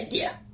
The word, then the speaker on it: Ghar: Gear? Like a worldly cog? – G-H-A-R. – But pronounced the same way. – Yeah, Gear. Alright.